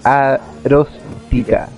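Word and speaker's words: Aróstica: ya empezar a buscar, ya Grande Gareth Grande Gareth eh, Camila Landaeta Arostica, con acento en la O.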